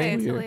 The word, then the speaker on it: Atelier: Is that how you pronounce that? – Yeah. – Atelier, as I called it the first year that I saw it. Is that, seriously, Atelier? – Atelier, yeah. – Is that French? – Yeah.